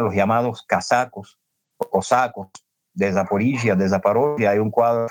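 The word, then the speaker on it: Zaporizhzhia: que es Zaporozhye, en ucraniano se dice Zaporizhia,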